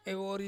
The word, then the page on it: vori: Ansus